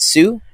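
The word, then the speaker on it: Hsu: Is it Sue? Yep. Uh, from Everything Everywhere All at Once. You don't say the H.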